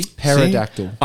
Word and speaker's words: pterodactyl: Pterodactyl. – I feel that pterodactyron would start. – No, is it same. A pterodactyl? But yeah. It's a silent pterodactyl. – Yeah, silent middle. – Nah. Silent P.